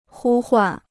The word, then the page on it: 呼唤: hū huàn